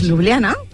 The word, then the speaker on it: Ljubljana: Ells escriuen Jota i nosaltres pronunciem I. – Ells escriuen amb Jota i nosaltres amb I. – Ljubljana! – Doncs no m'ajuda, pues, no m'ajuda gens. – Pues Ljubljana, t'estic dient.